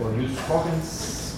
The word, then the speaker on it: jus cogens: o jus cogens, quiero pronunciarlo en español.